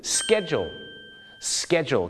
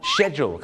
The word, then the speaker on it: schedule: แต่ขอซื้อนิดนึงครับบ้านเราชอบออกเสียง schedule schedule นะฮะชาวอังกฤษเนี่ยนะครับจะออกเสียงไม่เหมือนอเมริกาครับอเมริกาจะคล้ายๆของคนไทยครับก็คือ schedule ครับแต่ปรากฏว่าชาวอังกฤษหลายคนมากนะครับก็จะออกเสียงว่า schedule ครับผมชอช้างนะฮะ schedule นะฮะคล้ายๆชอช้าง she นะฮะ she schedule น